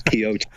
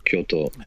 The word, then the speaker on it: Kyoto: it usually does come out as Kyoto, but it's Kyoto, the pronunciation.